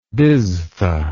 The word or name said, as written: biztha